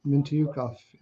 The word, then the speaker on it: Mintyukov: They've been getting a lot of Mintyukov. I don't even know how to say the guy's name. – That's right. That's how you say it. – What? – That's how you say it. Mintyukov.